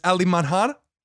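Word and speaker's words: Alemanha: In Portuguese, as Alemanhar. I hope I said that right. – Nailed it.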